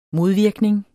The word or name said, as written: modvirkning